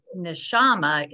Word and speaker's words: Neshama: Neshama is what, and you said it right, Jacob.